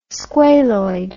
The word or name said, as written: squaloid